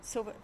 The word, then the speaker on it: Silva: There was mention of Lula da Silva. If I said that properly? Silva, yeah. And Evo Morales.